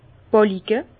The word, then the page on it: d' Bàlike: Bas-Rhin; Herrlisheim